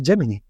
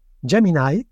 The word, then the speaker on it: Gemini: Por cierto, ahora que digo Gemini, esta semana me he enterado de que en Estados Unidos se dice Gemini. Gemini, que es como yo lo pronunciaba y creía que se pronunciaba, se dice solamente en Reino Unido.